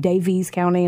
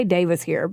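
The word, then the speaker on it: Daviess: Now, I underline Davis because I know it's spelled like Davies. So, like, some of the things, like, there's a video I watched, too, and they were saying Davies County. And I was like, we say Davis here, but Davies is in Indiana.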